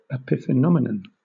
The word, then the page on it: epiphenomenon: British English